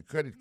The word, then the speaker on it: credit: Alors, nan mot crédit là, vous regardez pour accent tombé sur premier syllabe. Can I use my credit card for that? Alors, ou plus accentué sur cré.